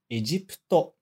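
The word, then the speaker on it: Egipto (エジプト): E a palavra em destaque aqui é Egipto, o Egito, né? De novo, esse PU no meio, a gente não vai pronunciar o U. A gente vai pronunciar só como se fosse o P. Egipto, ok?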